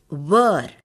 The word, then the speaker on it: were: So W-H-E-R-E wear and W-E-A-R wear have the same pronunciation, but W-E-R-E is pronounced wear. We will also take a look at some more mispronounced words. B-E-A-R.